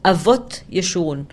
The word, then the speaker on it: Avot Yeshurun: Ayot Yeshurun. Did I pronounce it somehow right? – It's a pseudonym. His name is Avot Yeshurun.